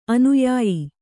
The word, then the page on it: ಅನುಯಾಯಿ: anuyāyi